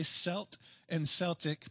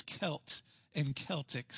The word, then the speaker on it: Celt and Celtic: Now, I'm going to tell you right up front, I've watched the Boston Celtics play so many times, I'm going to say Celt and Celtic, but I believe the pronunciation is Celt and Celtic.